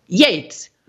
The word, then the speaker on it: Yeats: And I remember the teacher said, because everybody would pronounce it Yeats, because of course the great poet Keats. And so my teacher said, you can remember it. You just remember it. It's Yeats, Yeats, Yeats.